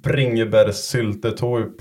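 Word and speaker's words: bringebærsyltetøy: Och varför har ni bringebärssyltetåg på allt ni äter? Nu om jag uttalar det rätt. – Men... Ja, det var riktigt faktiskt.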